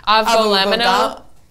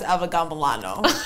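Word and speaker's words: avgolemono: How do you say in Greek? – Avgo lemono. – That's I'm pretty sure it's pronounced avgambolano. Pretty sure. – I have ordered enough avgo lemono in my life to know that it's that. Avgo.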